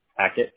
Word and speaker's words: Hackett: Cameron, do you spell it as C-A-M-E-R-O-N? – Yes, sir. – Okay, and your last name, please? Is it Hackett? – Hackett, H-A-C-K-E-T-T.